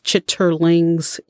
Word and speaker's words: chitterlings: I do know that occasionally she would make chitlins. And again, if you know, you know. But I'm sorry, the proper way to pronounce them is chitterlings.